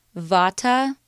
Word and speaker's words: Vata: Vata, which is spelled V-A-T-A, Pitta, P-I-T-T-A, and Kapha, K-A-P-H-A. Vata, Pitta, and Kapha.